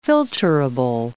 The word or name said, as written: filterable